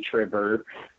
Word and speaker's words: Trever: You can find me on Facebook, Instagram, and Twitter as Trevor Eon. Trevor is spelled T-R-E-V-E-R. Everybody thinks it's Trevor, but my name is actually Trevor. My Eon is spelled A-E-O-N as opposed to E-O-N.